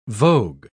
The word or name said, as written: vogue